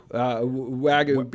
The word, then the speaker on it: Wagyu: Wagyu? – Wagyu beef.